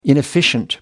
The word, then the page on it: inefficient: American English